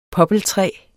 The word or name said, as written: poppeltræ